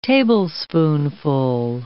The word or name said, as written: tablespoonful